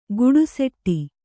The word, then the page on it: ಗುಡುಸೆಟ್ಟಿ: guḍuseṭṭi